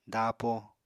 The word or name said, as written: daapo